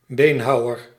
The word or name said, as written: butcher